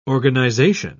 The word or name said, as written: organisation